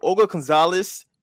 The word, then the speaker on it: Olga González: So Olga González is the CEO of Pietra Communications.